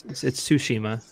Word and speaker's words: Tsushima: It's Tsushima. I know. There's no T. T's not pronounced in Tsushima.